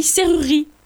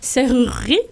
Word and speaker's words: serrurerie: on dit pas comme ça, on dit serrurerie. – Ah ouais, ah oui. – Ah oui, c'est comme dans certains mots où les E, ils sont pas prononcés. Ils sont muets, et bien là, c'est ça. Et bien, il faut bien avoir en tête que le premier E n'est pas muet, serrurerie, mais que le deuxième, et ben tu sais pas pourquoi.